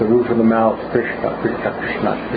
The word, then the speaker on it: Krishna: But it's, Yes, it's like R-U, Krishna. – Mm. – We say Krishna.